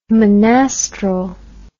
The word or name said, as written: monastral